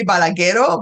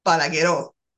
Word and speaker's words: Balagueró: you kind of want to say Balagueró, but it has an accent on the O, so it's Balagueró.